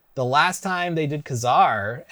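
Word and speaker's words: Ka-Zar: And then, how do you say his name? Kazar! – Yeah, Kazar.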